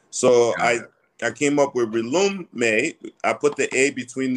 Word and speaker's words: Relume: Relume. – So yeah, the word relume means to rekindle. It's R-E-L-U-M-E.